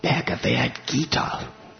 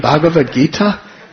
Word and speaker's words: Bhagavad Gita: He said, when I pulled it out, it was a Bhagavad Gita. Bhagavad Gita. I said, do you mean Bhagavad Gita? He said, is that how you say it? I said, yeah.